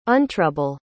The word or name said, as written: untrouble